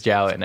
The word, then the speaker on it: Jowett: We may or may not had to just double check how to pronounce Jowett. We're pretty sure it's Jowett now.